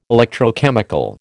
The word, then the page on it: electrochemical: American English